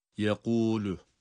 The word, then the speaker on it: yekûlü: Yani buradaki kaf harfi kalkale harfidir ama bakın hem kalın okunuyor. Yekû kalkale harfidir ancak kalkale yapabilmemiz yani sesi böyle patlatarak çıkarmamız cezimli halinde olur, harekeli halinde değil. Onun için olması gereken okuyuş yekûlü yekûlü ses budur.